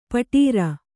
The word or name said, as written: ಪೌಡರು